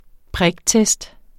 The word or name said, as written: priktest